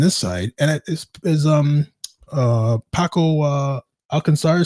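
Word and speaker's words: Alcácer: I think his name is Alcacer. – Alcacer. It's Alcacer.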